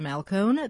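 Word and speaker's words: Malcohn: Do a web search on my name, Elisa Malcone, that's spelled E-L-I-S-S-A-M-A-L-C-O-H-N, or do a web search on Malcone's World.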